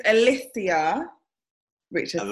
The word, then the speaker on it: Alicia Richardson: So it's Alicia Richardson.